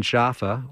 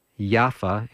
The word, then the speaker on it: Jaffa: And I also stayed a couple of nights in Jaffa, or as in Arabic they say, Yaffa. In Hebrew they say Yafo, and we Americans say Jaffa.